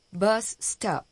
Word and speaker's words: bus stop: La U de bus se pronuncia como la de pub. La O de stop es breve. Se parece a la O de noticia. Repita. Bus stop.